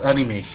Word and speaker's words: Animesh: It's Animesh, yeah.